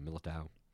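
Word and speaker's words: Militão: Militao.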